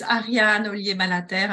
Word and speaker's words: Ariane Ollier Malaterre: So my name is Ariane Ollier Malaterre. I think you did a great job, you know, pronouncing it anyways. It's not very easy if you're not a Francophone.